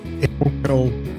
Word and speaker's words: inaugural: Yeah, I didn't realise I was the inaugural. How do you even pronounce that word? Inaugural? – That's it. Yeah.